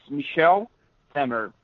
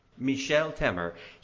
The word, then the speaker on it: Michel Temer: Okay, so the current president, is his name pronounced, is it Michel Temer? – It's Michel Temer. – Michel Temer.